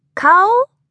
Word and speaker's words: caul: Caul? I said, as if it was the word cold.